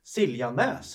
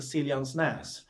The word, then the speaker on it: Siljansnäs: Han säger så här, Siljanäs säger han, och det heter Siljansnäs.